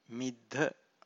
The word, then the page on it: middha: Pali